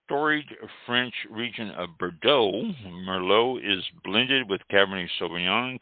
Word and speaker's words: Merlot: The T is silent, pronounced Merlot.